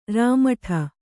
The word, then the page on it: ರಾಮಠ: Rāmaṭha